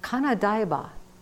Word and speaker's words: Kana Daiba: This Kana Daiba is actually in Sanskrit Kana Deva. So Kana Daiba, Japanese pronunciation.